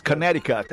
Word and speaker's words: Connecticut: Connect Cut. – Claro, tem a mesma Connect Cut. Exatamente, Connecticut.